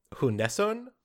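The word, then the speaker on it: Sjunneson: Her last name is Swedish, and I'm going to pronounce it the best I can and say Hunesson, which is the way that she has it sort of phonetically spelled out on her website.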